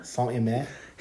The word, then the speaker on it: Saint-Imier: Earlier this summer, the Anarchy 2023 festival in, how do you say this pronounced? Saint-Imier? – Saint-Imier. – In Saint-Imier. – Saint-Imier. Imier.